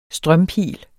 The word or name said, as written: strømpil